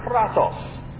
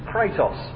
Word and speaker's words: kratos: The Greek word for power is kratos, which in the company that I work for is mispronounced as kratos.